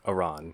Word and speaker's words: Aran: Although I do think that Zeke told me that that's how he pronounces Aran.